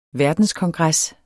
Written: verdenskongres

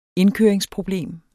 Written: indkøringsproblem

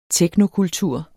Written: teknokultur

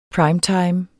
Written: primetime

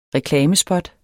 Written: REKLAMESPOT

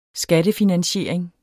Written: skattefinansiering

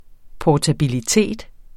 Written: portabilitet

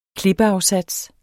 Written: klippeafsats

